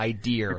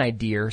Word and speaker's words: idea: Ideer. – It's insane. – I got an idea. Stop pronouncing it with an R.